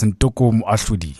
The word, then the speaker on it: Ntoko Moahloli: Yeah, it's Ntoko Moahloli, yes.